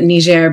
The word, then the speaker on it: Niger: So I was in Niger, and I pronounce it Niger because it's the French pronunciation.